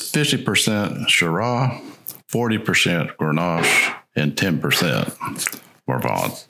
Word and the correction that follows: Mourvèdre: How do you say it? Mourvèdre. Mourvèdre. I'm not French.